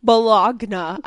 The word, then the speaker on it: Bologna: Bologna, I believe is how it's pronounced.